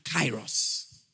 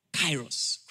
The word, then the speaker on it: kairos: The first, chronos, and the second, kairos. Chronos is spelled C-H-R-O-N-O-S. Kairos is spelled K-A-I-R-O-S.